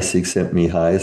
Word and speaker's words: Csikszentmihalyi: And again, to quote the famous Hungarian psychologist whose name is Mihaly Csikszentmihalyi, some of your, it isn't spelled like that because there's all kinds of L's in there that apparently are not pronounced in Hungarian.